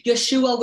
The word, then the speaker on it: Yeshua: Yeshua without the H at the end. But either way, pronounced the same way.